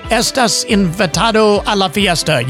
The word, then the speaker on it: estás invitado a la fiesta: Did I say that right? Estás invitado a la fiesta. You're invited to the party. – I like that. You did a very good okay.